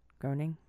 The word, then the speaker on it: Groening: Is it Groening, not Groening? – It is Groening. I looked up the pronunciation.